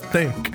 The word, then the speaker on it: Thimk: it's like Thimk, but with an M instead of an N. So it's pronounced Thimk.